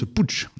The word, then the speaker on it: Puig: Un certain, Joan Etxepuig, on prononce Puig d'ailleurs en catalan, Joan Etxepuig à la contrebasse.